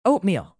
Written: oatmeal

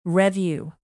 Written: revue

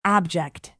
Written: abject

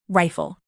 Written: rifle

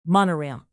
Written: monorail

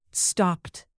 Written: stopped

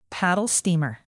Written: paddle-steamer

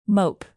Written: mope

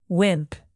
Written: wimp

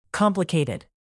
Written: complicated

